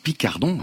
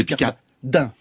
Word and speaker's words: Picardan: Vous dites Picardon, hein ? – Picardin. – C'est Picardin. – P-I-C-A-R-D-A-N. – Et ça se prononce Picardin.